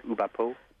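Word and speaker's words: OuBaPo: Yeah, and Ayrola is a member of the UBAPA. Am I saying that right? – UBAPO. – UBAPO organization, the comics formalists that people like Matt Madden here in North America sort of belong to.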